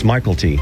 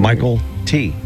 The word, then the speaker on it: McKelty: It's not. It's Michael T. – Oh. – Mm-hmm. Michael T, even though it's spelled McKelty.